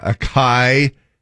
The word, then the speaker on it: acai: Yeah, a kai. A C A I. Uh, it's pronounced acai.